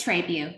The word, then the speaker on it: Trabue: Did I just say your name correct? – It's Trabue, but that's okay.